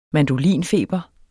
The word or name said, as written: mandolinfeber